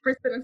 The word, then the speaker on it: Brisbane: Am I saying that right? Brisbane? Yes. – Brisbane, Australia, yeah.